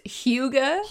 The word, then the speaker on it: hygge: They cut down Hygie? – I think it's pronounced Hygge. – Hygge, that sounds right.